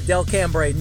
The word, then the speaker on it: Delcambre: D-E-L-C-A-M-B-R-E is how you spell it. It's so funny when people come in at Delcambre. No, it's Delcambre.